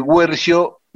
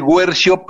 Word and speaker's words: Guercio: Está bien, se escribe sin diéresis, pero se pronuncia como paraguas. – Ah. – Es del Guercio, pero porque es italiano, entonces se pronuncia así.